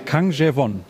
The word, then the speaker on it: Kang Jae-won: Kang Jae-won ist richtig ausgesprochen, ja? – Das ist richtig, ja.